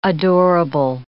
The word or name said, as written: adorable